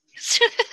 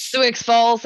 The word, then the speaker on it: Sioux Falls: Sioux. – Sioux Falls. – Sioux Falls, South Dakota. – It's a silent X. – It's a silent I, too, in that case.